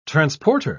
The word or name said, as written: transporter